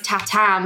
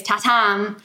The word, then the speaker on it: Tatum: Everyone spells my name wrong because it's Tatum, but it's... Like, people try and write T-A-T-U-M. – Oh, yeah, yeah, it's Tatam. – It's Tatam, but I don't say... My name is Tatam.